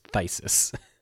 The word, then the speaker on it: Phthisis: Phthisis. Is that Fibblethip's other cousin? – Close. Very close. No. The way that I've always pronounced it is Thysis. The PH makes a... It's silent. It's just Thysis.